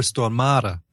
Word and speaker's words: Stormare: And it's pronounced Peter Stormare, by the way.